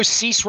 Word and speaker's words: Cease: I really wonder, and while we're opening the whole can of things, and I know Cease rhymes with lease, and John Angelos doesn't want to hear that, but the Cease thing to me was the bigger banana to me.